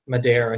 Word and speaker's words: Madar: Yam Madere, I think, is how we've decided to pronounce his name, a.k.a.